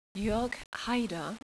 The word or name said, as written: Haider